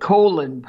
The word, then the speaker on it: Colin: in the colon, I have to remember how to say it, Colin Powell comment from the president.